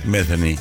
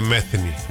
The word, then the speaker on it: Metheny: Metheny. – Pat Metheny. ¿Es un modelo de celular? – No. Se escribe TH y con Y. – Parece Pat. Metheny.